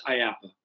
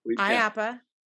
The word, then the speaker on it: IAAPA: Would not have been how I predicted pronouncing the acronym, but I like it. IAAPA. So everybody refers to it as IAPA. – IAPA.